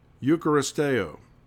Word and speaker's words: eucharisteo: The Greek word for thanks is eucharisteo. And the spelling of the word in English is E-U-C-H-A-R-I-S-T-E-O.